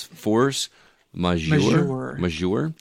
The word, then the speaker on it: Force Majeure: Force Majeure.